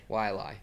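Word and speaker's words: Waialae: Who's going to win at Wailalai? Not good at English. – Wailai. – It's like two syllables.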